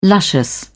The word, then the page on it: luscious: British English